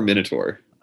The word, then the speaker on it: Minotaur: Is it Minotaur or Minotaur? – I pronounce it Minotaur, but whatever.